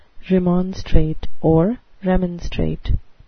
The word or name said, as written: remonstrate